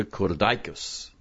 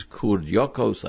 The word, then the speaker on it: kurdyokos: We would pronounce this word in yeshiva kurdaikos. But I see in the various editions of the Mishnayos, which have nekudos, that the correct pronunciation is kurdyokos.